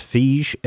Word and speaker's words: Feige: Well, I think his name is pronounced Feige, F-E-I-G-E, Feige, is like the producer of this.